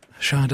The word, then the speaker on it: Sade: Was it Sade? – Sade, it's pronounced. – With the wine. – Sade, yeah, Sade.